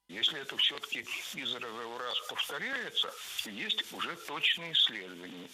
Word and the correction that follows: из раза в раз: Из раза в раз. Здесь, в этом выражении, ударение поставили на предлог. Но это неверно. Должно быть из раза в раз. Предлог оставляем безударным.